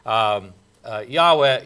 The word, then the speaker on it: Yahweh: We have talked about it before, but Yahweh is probably the way this name, holy name of God, was pronounced. And with the A and the E added in as vowel sounds between the four letters.